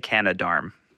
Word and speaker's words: Canadarm: I say Canadarm.